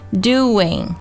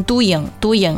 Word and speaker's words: doing: 这个 do 的音，不要用中文里欢度春节的度去替代它，它的元音呢是一个 oo 的音，而不是中文里乌鸦的乌啊，要注意 do 然后加上后面的 ing ing 的音，就是 doing，中间呢会用一个非常轻非常快的 w 的音去连接这个 do 和 in 这两部分， doing 不要读成 doing 啊。